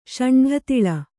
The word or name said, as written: ಷಂಢತಿಳ